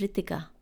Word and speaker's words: Ritika: My name is Ritika, right? And that, so it's spelled as R-I-T-I-K-A. So the T sound in the language that I've been named in, there's two different sounds. There's a T for tomato, and then there's a T, which is like almost like TH, but not quite.